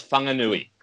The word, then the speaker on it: Whanganui: JC, when a W and an H are pronounced together in New Zealand, it's Whanga. So that's Whanganui.